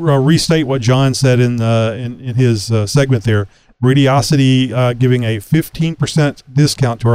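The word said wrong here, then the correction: Radioddity: That's Radiodity? I think I just said it wrong. – Radiodity. – Radiodity. Yeah.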